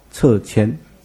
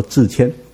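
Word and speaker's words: 掣签: 记得哈，撤签不读作自签。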